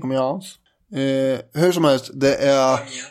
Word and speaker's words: Amiens: 530 brittiska och 70 franska pansarvagnar är som koncentreras vid staden Amiens. – Mm. – Rätt uttalat? – Ja. På svensk-franska så är det väl det.